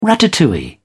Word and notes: ratatouille: British English

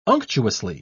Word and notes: unctuously: British English

